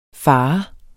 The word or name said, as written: fare